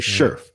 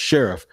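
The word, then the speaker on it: Scherff: So Brandon Sheriff, and I used to say Sheriff, but it is pronounced Sheriff.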